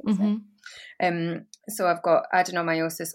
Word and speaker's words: adenomyosis: And so we started doing the test early 2020, um, and they identified that I had adenomyosis. Is that how you say it? – Uh huh. Yeah.